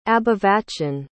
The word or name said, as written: abhivachan